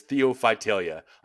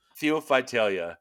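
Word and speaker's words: Theiophytalia: Uh, your first dinosaur is Theophytalia. I'm using a, a pronunciation dictionary because I was like, it would be so incredibly unfair for me to uh, just butcher a dinosaur's name and be like, go ahead, spell it with like no zero clues.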